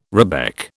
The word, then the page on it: rebec: British English